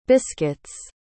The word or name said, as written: biscuits